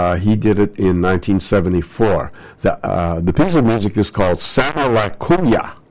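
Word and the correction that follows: Sama Layuca: or Sama Le Kuya. And no, I'm pronouncing it wrong. Sama Leuka. Yes, yes. Gee, get it right.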